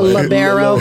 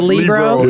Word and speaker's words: libero: A libero versus a libero. – Yeah, so you can pronounce it both ways.